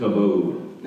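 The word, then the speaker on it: kabod (כבוד): It's a Hebrew word called, looks like kabod. It's pronounced kabod.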